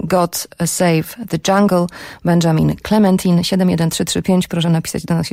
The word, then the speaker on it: Clementine: że powinnam to nazwisko wymówić Clementine, ale on sam, będąc tu przy Myśliwieckiej kilkanaście miesięcy temu, zażyczył sobie, żeby go wymawiać z francuska Clementine,